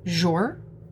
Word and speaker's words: Jour: It's called Jour. – How do you spell that? – J-O-U-R. – Like soup du jour? – Yeah.